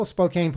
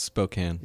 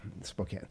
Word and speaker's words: Spokane: Oh, am I pronouncing it wrong? – Yes, it's Spokane.